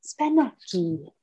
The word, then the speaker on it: spanaki: You got to pronounce it with the right finesse. Spanaki. So I think you're right.